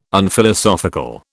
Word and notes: Unphilosophical: British English

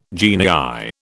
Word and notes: genii: British English

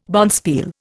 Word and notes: bonspiel: Indian

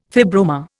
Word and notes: fibroma: Indian

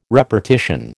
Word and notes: repartition: American English

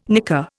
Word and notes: nicker: Indian

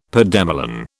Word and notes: pademelon: British English

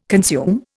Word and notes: consume: Indian

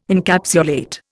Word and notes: Encapsulate: Indian

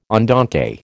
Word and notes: andante: American English

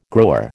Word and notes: grower: American English

